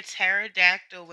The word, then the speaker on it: pterodactyl: You can't hear a pterodactyl when they use the bathroom. Because the P is silent.